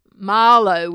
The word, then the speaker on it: malo: Well, Marlo with a long A.